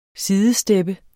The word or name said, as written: sidestep